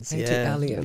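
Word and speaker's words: ATLiens: ATLians. ATLians.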